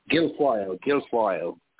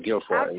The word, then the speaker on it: Guilfoyle: So it's might be either Ivanka or Melania, or possibly Kimberly Guilfoyle or Lara Trump. – Guilfoyle. That's Donald Trump Jr.'s girlfriend. – Yeah. – Kimberly Guilfoyle. That's how you pronounce her name.